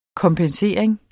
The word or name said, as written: kompensering